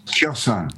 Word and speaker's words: Kherson: Kherson.